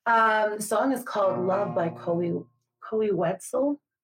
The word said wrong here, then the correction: Koe Wetzel: I don't know if I'm saying his name properly. Koe Wetzel, I on Spotify.